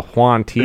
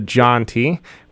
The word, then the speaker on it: Jauntee: Your name is spelled J-A-U-N-T-E-E. – Yep. – So if you guys are Latin family, you may have someone named Juan in your family, so you might mispronounce it and say it the Juan T. – Yes. – It's actually the John T, which Juan is John in Spanish.